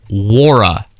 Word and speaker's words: Nwora: Wara. – Right. – Silent N. – Yep. – On that. So N-W-O-R-A, but the Louisville, they could do Louisville hat night for him.